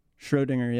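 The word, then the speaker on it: Schrödinger: Schrodinger, yeah.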